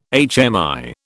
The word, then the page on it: HMI: British English